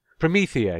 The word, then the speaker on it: Promethea: I should perhaps explain the baffling reference to Promethea. Is that have I pronounced it correctly? Promethea. – Written by Alan Moore. Promethea, yes, of course, Promethea,